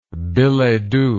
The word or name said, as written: billetdoux